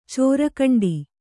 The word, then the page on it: ಚೋರ್ಗಂಡಿ: cōrgaṇḍi